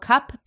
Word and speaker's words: Cuup: So my first one is bras from a brand called Cup, but it's spelled C U U P.